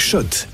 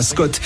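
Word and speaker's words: Schott: Jouer et gagner sur RMC avec Shot, la marque iconique new-yorkaise depuis 1913. Oui, Shot et pas Scott. Ça fait des années qu'on prononce mal la marque.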